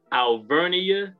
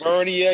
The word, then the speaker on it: Alvernia: Alvernia University. – Alvernia University, located where? – In Redding, Pennsylvania, baby.